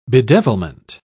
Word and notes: bedevilment: British English

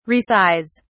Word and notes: resize: British English

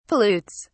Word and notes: pollutes: British English

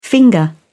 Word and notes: finger: British English

